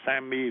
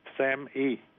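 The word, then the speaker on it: SAMe: Thuốc tên là SAMI, viết là SAM và cái chữ E nhỏ. Từ người Mỹ họ đọc là SAMI,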